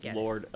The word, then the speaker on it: Lord: Chris Lord, L-O-R-D. – L-O-R-D.